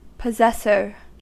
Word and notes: possessor: American English

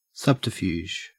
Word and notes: subterfuge: Australian English

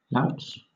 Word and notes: louts: British English

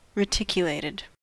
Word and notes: reticulated: American English

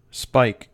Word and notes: spike: American English

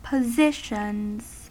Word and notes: positions: American English

